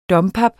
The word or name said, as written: dompap